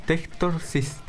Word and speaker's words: Textorcist: Textorcist.